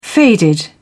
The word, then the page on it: faded: British English